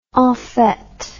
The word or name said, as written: enfete